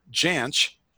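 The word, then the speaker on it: Jansch: And what I'm doing is I'm setting up a path here because it was recorded by Burt Janch. Hopefully I'm pronouncing that fairly. – I think so. Yeah, that sounds like what I've heard pronounced.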